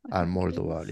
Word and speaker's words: Almodóvar: Almodóvar.